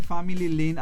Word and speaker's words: Family Link: Este Family Link, así se dice de Google, Juancito.